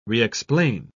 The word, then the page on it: re-explain: British English